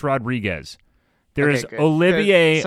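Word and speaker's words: Rodrigues: There is Olivier Rodrigue. That is a player, and that is spelled as Rodrigue. There's no S. But Evan Rodriguez is pronounced that way by the...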